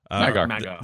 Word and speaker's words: Mag'har: Magar. – They're ready to go if you have the rep. – if you're an alliance human, maybe. – Yeah. So Dark Iron Dwarves look amazing. The Magar orcs look like orcs.